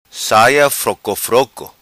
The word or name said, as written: Saia Froco Froco